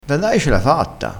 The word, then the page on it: da dai: dialettale